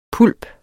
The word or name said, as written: pulp